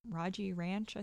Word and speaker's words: Rogge: and it is taking place Rogge Ranch, I think is how you say it. It's R-O-G-G-E Ranch, and that's in Austin, Texas, of course.